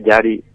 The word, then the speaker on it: Jari: La J se pronuncia como griega, ¿no? Entonces sería Jari Ronkainen, pero se escribe Jari Ronkainen.